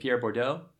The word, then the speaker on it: Pierre Bourdieu: One last bonus book, I think you pronounce it Pierre Bourdieu, writing his book is called Distinction, A Social Critique of the Judgment of Taste.